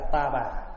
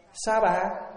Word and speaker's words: sa bà: Chữ sa ha mà âm là ta bà, mà nếu âm cho đúng thì đọc là sa bà. Mà sao mình đọc là ta bà? Vậy mà mấy chục năm nay mình cũng ta bà có sao đâu, phải không?